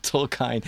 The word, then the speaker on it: Tolkien: Tolkien.